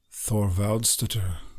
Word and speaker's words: Thorvaldsdottir: Thorvaldsdottir. – Oh, Thorvaldsdottir.